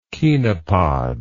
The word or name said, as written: chenopodiaceous